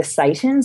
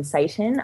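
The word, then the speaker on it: seitan: I know the sorts of things that you're talking about, and they're the things like, you know, the Satan. So it's spelled S, not like Satan as in the devil. It's S-E-I-T-A-N, Satan, okay.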